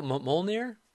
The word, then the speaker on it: Mjolnir: Mjolnir? – Mjolnir, yeah.